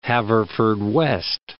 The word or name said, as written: Haverfordwest